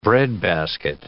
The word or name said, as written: breadbasket